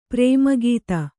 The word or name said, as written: ಪರಮಗತಿ